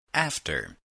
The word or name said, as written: after